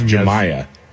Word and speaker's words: Jamyah: I think it's Jamya. – So it's just J-A-M-Y-A-H. – Yeah, Jamya.